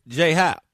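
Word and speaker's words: J.A. Happ: Happ, or is it Jay Happ? – It's just Jay, yeah. It's just pronounced Jay, but I mean, I obviously understand with the initials there, J-A, too, as well.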